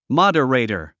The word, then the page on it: moderator: mɑ’dərèitər; マダレイタァ